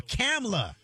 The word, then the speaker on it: Kamala: I think Why are you adding an extra syllable, both of y'all? Kamala. – Kamala. – Not Kamala or Kamala. Kamala Harris.